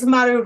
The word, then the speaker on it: Maru: Maru, I've been pronouncing your name not accurately for all this time. Would you be willing to say it accurately for me? – Yeah, it's Maru Rojas.